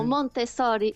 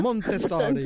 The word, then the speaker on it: Montessori: Uh, eh, disse Montessori em português Sim. ou Montessori em italiano. – Montessori, Portanto, muito bem.